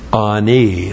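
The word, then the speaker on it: Ani (עני): There's the ayin. You have to actually close the throat and then start the word, and A-N, long I. Ani.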